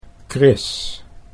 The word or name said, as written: Chris